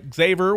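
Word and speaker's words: Xaver: It's X-A-V-E-R. Xavier? – It's Xavier? – Yeah, it's Xavier without an I.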